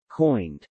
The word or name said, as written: coined